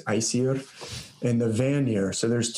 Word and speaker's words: Aesir: It was the first battle is between the Aesir gods, or actually I'll pronounce it Aesir. That's how I've heard Carolyn Larrington. I listen to her pronunciations very carefully and use them as much as possible because she's obviously an authority. So she calls it Aesir.